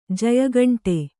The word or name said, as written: ಜಯಘಂಟೆ